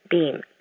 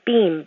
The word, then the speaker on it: Beam: It is Chris Beam.